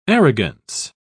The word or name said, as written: arrogance